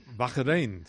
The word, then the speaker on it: Bahrein: ¿A qué se pronuncia así? Bahrein. – Bahrein, sí, sí, está bien, está bien. Bahrein, pero no me digas cómo... – No, Bahrein. – Bahrein mejor en españolizado, perfecto.